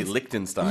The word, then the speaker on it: Liechtenstein: In English, we say Liechtenstein, Liechtenstein.